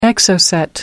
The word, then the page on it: exocet: American English